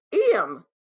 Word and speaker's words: Iam: But what I also love is that his name is spelled I-A-M. I am. He pronounces it, or I guess his parents, when they named him, pronounce it Iam. But it's I am.